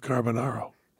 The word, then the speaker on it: Carbonaro: And Nathan Santucci said to me, just so you know, for the poem, Kilimanjaro rhymes with Carbonaro.